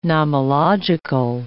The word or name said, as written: nomological